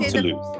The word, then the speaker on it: Toulouse: We, we did I say that? Well, it's did from Toulouse. We, okay. It's from okay. Toulouse. So I did say it right.